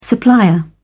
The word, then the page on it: supplier: British English